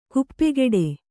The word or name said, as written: ಕಾಪುಗಾಡು